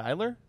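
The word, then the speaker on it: Iler: How do you say that? – Eiler? – Robert Eiler? – Yeah.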